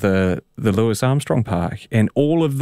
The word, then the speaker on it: Louis: You were right the first time when you said Louis. It's a common mistake to pronounce L-O-U-I-S as L-E-W-I-S. L-O-U-I-S is pronounced Louis, as in Robert Louis Stevenson, Louis the 14th, etc.